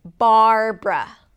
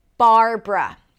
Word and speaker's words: Barbara: The next name is Barbara, Barbara. This name has two syllables. The first syllable is bar. Bar, start with a B sound, close your lips.